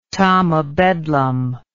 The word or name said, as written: tom o bedlam